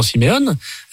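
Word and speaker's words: Simeoni: Vous avez une manière de prononcer Simeone sans dire le i qui est assez appuyé.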